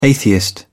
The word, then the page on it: atheist: British English